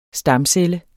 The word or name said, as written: stamcelle